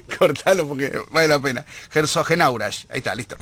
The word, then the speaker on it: Herzogenaurach: Sí, hay una joda que le hacíamos a Marcelito porque la primera vez que lo pronunció dijo Herzogenrausch. – Ya está la selección nacional en la ciudad de Herzogenrausch. Herzogenaurach, perdón.